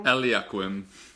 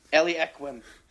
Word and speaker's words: Eliaquim: And he's probably going to be a little bit slightly more offensive than how do you say his first name? Equilium? – Eliaquim. – Eliaquim.